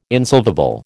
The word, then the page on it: insolvable: American English